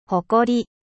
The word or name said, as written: ほこり